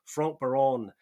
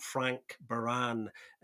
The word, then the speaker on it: Franck Baron: And you have to put a little bit of a French spin on the pronunciation to kind of get close to it. So Franck Baron, not Frank Baran, as some people like to call them, and we nearly did.